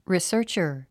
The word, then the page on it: researcher: risə́ːrtʃər; リィサァチャァ